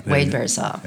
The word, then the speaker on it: Wade Berzas: Wade Berzas.